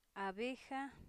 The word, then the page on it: abeja: a'bexa